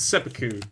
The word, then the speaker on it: seppuku: I don't know if I'm saying it right. Seppuku is that officiation? Yeah, it's seppuku.